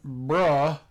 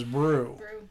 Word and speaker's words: Brugh: I was pronouncing it bruh because it's B-R-U-G-H, but apparently we went on the creepy voice people and they said it was brew.